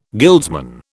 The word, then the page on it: guildsman: British English